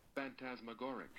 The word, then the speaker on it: phantasmagoric: Phantasmagoric. – Oh dear. What does it mean? – No, wait, let me just play the computer to check that I pronounced that right. – Phantasmagoric. – Yeah, perfect.